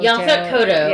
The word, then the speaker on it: Yaphet Kotto: Yaphet Kotto.